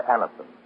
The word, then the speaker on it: Anacin: So if you want really fast relief from headache, neuritis, or neuralgia pain, try Aniston. For most effective relief, use only as directed. It is spelled A-N-A-C-I-N, and you can get it at any drug counter.